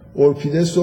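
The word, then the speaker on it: اوریپید: حالا به تلفظ ساده تر که توی کتابا مینویسن، اورپید رو انحطاط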